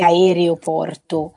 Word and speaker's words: aeroporto: errata, quindi la, la, pronuncia corretta è aeroporto.